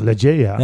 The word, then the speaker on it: Legea: Ik zeg Legea.